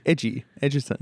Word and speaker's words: Egerton: Edgerton. How the fuck do you pronounce his surname? – Edgerton.